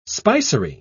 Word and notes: spicery: British English

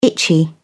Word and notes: itchy: British English

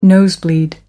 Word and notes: nosebleed: American English

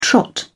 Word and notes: trot: British English

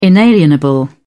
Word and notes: inalienable: British English

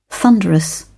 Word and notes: thunderous: British English

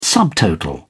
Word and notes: subtotal: British English

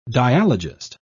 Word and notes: dialogist: British English

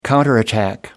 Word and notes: counterattack: American English

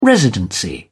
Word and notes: residency: British English

